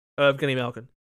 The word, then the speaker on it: Evgeny Malkin: Evgeny Malkin? – Evgeny Malkin.